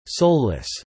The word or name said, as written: soulless